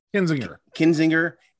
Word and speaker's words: Kinzinger: How do you say his name? – Kinzinger and Cheney.